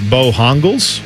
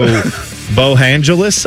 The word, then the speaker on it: Bojangles: Although I saw somebody yesterday on Twitter make a great point that I've heard people call it Bohongles or Bohongles, but Bohongles, we should actually pronounce Bojangles like Los Angeles. So Bohangeles, I guess is how we would say it, which I'm also good with.